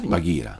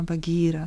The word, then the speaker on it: Bagheera: A panterę jak się wymawia? – Bagheera. – Ma Bagheera, tak, dobrze.